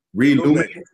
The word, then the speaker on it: Relume: Relume. – So yeah, the word relume means to rekindle. It's R-E-L-U-M-E.